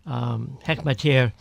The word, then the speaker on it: Hekmatyar: And when going back to Afghanistan in the 80s, you had this Gulbuddin Hekmatyar, pronounce his name, Hekmatyar, who is one of the most vicious warlords, is now, it seems, back welcome in the Afghan government.